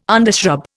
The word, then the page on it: undershrub: Indian